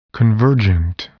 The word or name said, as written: convergent